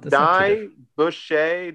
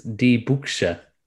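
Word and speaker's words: Die Büchse: Second half, the easy half was fine. It's Die Buchse. Die Buchse. Die Buchse. Yeah, you have the umlaut over the U, so it drags out the U sound.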